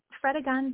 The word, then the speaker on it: Fredegund: And outside of Broomhild, of course, and is the name pronounced Fredegund? – Fredegund.